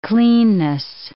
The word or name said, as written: cleanness